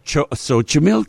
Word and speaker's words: Xochimilco: And do you know how to pronounce it? – Because it's like X something, right? – Yeah, it looks like it should be... – Xochimilco? – Xochimilco. – Xochimilco, yeah. – Xochimilco. It's X-O-C-H-I-M-I-L-C-O.